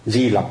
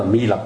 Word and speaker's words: Mi Lặc: Mi Lặc người tại vì chữ Mi với chữ Di thì họ đọc một thứ, hôm trước mình nói rồi phải không, cũng giống như Amida Di Đà đó, thì họ đọc là Di Lặc Bồ Tát.